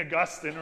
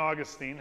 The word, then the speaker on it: Augustine: I also heard something this week that St. Augustine, or St. Augustine, however you choose to pronounce his name,